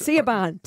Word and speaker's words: Siobhan: It's S-O-S. – Siobhan. Please say Siobhan.